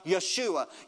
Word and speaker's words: Yeshua: There's no J in the Hebrew alphabet, it's pronounced with the Y sound, Yeshua.